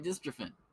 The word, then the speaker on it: dystrophin: Let's uh let's see how this word's pronounced. Dystrophin. Dystrophin. Something scary. Dystrophin. Oh, I'm getting it right. Dystrophin. Hey, look at me getting big words right.